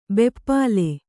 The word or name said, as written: ಭೂಪಾಲ